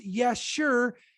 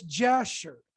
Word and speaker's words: Jasher: And it's pronounced Yasher. It looks like it says Jasher.